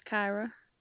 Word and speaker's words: Kyra: My name is Kyra. – How spell it? C-A-R-A? – K-Y-R-A. – K-Y-R-A.